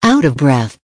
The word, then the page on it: out of breath: American English